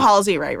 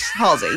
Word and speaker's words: Halsey: Okay, I do Halsey right? – Yes. – Halsey, yeah.